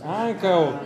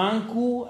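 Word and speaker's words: ankaŭ: Caballeros, ancao. Vamos, empates. ¿Quién va, Maritza? A ver, voy a pronunciar bien esa palabra. Esa palabra se pronuncia ancao. Ancao, esa es la correcta. No ancu, ancao, ancao.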